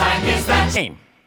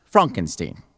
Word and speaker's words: Frankenstein: Frankenstein. – It's pronounced Frankenstein.